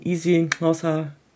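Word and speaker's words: isiXhosa: in Easy Hauser so äh, mit diesem click spricht man das immer aus, zu spielen.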